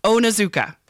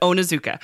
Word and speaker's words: Onizuka: I always try to write it down because I always want to make sure I say it right. Onizuka. There we go. There's for there's a lot of consonants and I always want to like mispronounce it, but Onizuka.